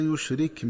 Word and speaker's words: يشرك: ينبغي التنبه للنطق الصحيح لكلمة يشرك، فتقرأ بتسكين الكاف وصلا ووقفا هكذا: ولا يشرك بعبادة ربه أحدا.